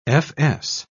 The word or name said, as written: fs